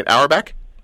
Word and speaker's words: Auerbach: Auerbach? – Auerbach is right.